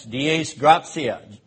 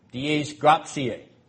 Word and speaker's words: Dies Gratiae: How do we say that in Latin? Dies Gratiae. Yes, Dies Gratiae. G-R-A-T-I-A-E. Dies Gratiae.